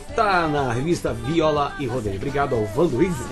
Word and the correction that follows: Janduí: é isso? Falei certo? – Janduí. – Janduí, Janduí. Tá aqui na capa com o Luan Santana, né?